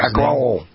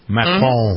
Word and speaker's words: Macron: Is that how you pronounce Macron. – Macron.